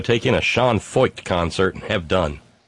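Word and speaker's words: Feucht: Yeah, Kid Mark in the chat room reminded me of the proper pronunciation, Foyt.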